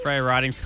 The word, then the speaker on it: Freya Ridings: Freya Ridings.